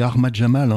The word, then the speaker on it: Ahmad Jamal: On va poursuivre donc euh, notre, notre voyage en compagnie euh, d'Armad Jamal on dit Ahmad Jamal. Hein, euh, bon, euh, Abdel est en train de nous fusiller parce qu'évidemment la prononciation est pas pas géniale, mais bon.